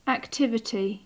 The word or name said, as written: activity